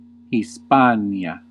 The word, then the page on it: Hispānia: Classical; /hisˈpaː.ni.a/